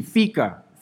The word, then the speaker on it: fika: Now, how do you pronounce this word? Easy, Fika. Fika.